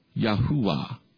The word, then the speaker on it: Yahuwah: However, after testing the sacred name in Israel over a period of 2 years, we came to conclude that the name was properly pronounced Yahuwah. That's what it says, Yahuwah.